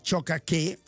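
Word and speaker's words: Tiohtià:ke: Esempio concreto, Montreal, che sappiamo tutti è Chocaque, e non è Montreal, anzi è Chocaque, che ho sentito... – Quindi l'ho sempre pronunciato male. – Beh, io ho sentito che la vera pronuncia è Chocaque.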